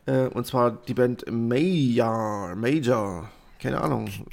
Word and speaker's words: Meijar: Ich habe gefragt, Major werden sie ausgesprochen. – Meyer, ja. okay. Und, und, zwar wird es buchstabiert äh, M-E-I-J-A-R.